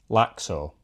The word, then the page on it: Laxo: Shaetlan